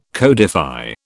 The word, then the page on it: codify: British English